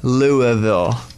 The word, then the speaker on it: Louisville: Oh, Louisville, Kentucky. – Ooh. – I know. I'm right, because people probably say Louisville. – No, they don't say Louisville. – What do they say? – It's actually Louisville.